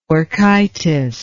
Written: orchitis

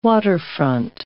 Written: waterfront

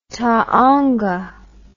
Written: taonga

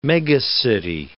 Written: megacity

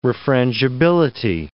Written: refrangibility

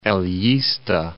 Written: Elista

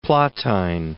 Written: Plautine

